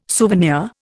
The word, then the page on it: souvenir: Indian